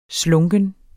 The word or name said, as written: slunken